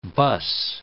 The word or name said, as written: bus